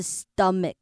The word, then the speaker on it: stomach: 好，这边这个 stomach 它不是 stomach，它是 stomach。好，你有没有发现其实后面那个 a 它出现的时间是很短的，mic。然后记得后面的 ch 是 k 的音。